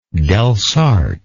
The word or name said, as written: Delsarte